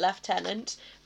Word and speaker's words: lieutenant: And yes, I am going to be pronouncing it lieutenant, because I'm English.